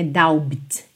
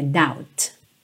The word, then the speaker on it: doubt: A letra B não soa, tá? Não é doubt, é doubt.